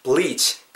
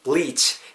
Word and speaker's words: bleach: 이렇게 되시고 뒷부분은 이제 뭐 bleach your hair 발음하실 때 bleach B-L-E-A-C-H 발음하실 때 bleach 약간 좀 길게 발음한다라는 그 기분 가지고 생각, 생각하시면서 발음해 주시고요. bleach. ch 발음하실 때, 치 하실 때, 취. 굳이 이렇게 입술 둥글게 말고 사운드를 내지 마시고요. 그냥 입의 양옆, 옆으로 그냥 자연스럽게 째면서 bleach, bleach. 이렇게 발음해 주시면 좋을 것 같아요.